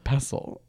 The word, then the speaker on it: pestle: I still haven't double checked this, but our friend said in the other episode when you were saying pestle, you were correct. Mortal and pestle. – Yeah, I know. Sorry. – Like, even though it is spelt with a T.